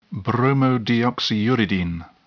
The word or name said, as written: bromodeoxyuridine